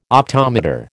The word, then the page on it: optometer: American English